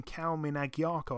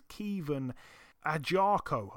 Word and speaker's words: Kevon Agyarko: And Kevon, I think I've been saying his name wrong for so long. I've been calling him Kalmin Agyarko. I think his name's Kevon Agyarko, I believe it's actually said.